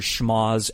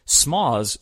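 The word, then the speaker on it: schmozz: Additionally, you may also want to listen for Jesse mispronouncing the word schmoz as smoz several times,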